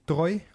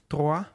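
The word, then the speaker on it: Troyes: Het is een beetje tot Bully, Chelsea, Troy. Of Troa, hoe spreek je dat Ja, nee, de tweede is ja.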